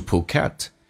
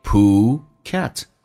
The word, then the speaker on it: Phuket: Let's go to Phuket. Phuket 指的是普吉岛，重音在后面 Phuket.